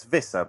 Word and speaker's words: Visser: Am I saying that right? – Yes, Visser? Absolutely. Yeah. – Or is it Visser? Like a... – Well, it's Visser, but you know, Visser.